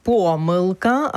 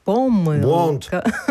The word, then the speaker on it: помилка: Наше сьогоднішнє слово дня – помилка або ж помилка. Ні, два наголоси допустимі. Це слово, яке має два допустимі наголоси.